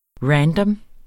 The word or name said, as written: random